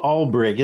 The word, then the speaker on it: Alberich: Albrich. It's spelled A-L-B-E-R-I-C-H, like Al B. Rich.